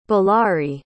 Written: ballari

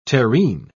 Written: terrene

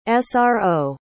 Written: sro

